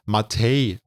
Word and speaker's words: MATE: Die Lösung ist Ubuntu Mate, was eigentlich als Matei ausgesprochen wird.